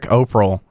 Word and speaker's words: Operle: So Derek Operl, I think that's how you say his name, O-P-E-R-L-E. – Operl. We'll go with Operl.